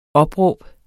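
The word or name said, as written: opråb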